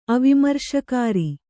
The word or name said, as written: ಅವಿಮರ್ಶಕಾರಿ